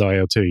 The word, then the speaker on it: IL-2: So there's a drug, it's called interleukin-2, but you pronounce it as IL-2, you just shorten it to that.